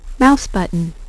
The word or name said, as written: mouse button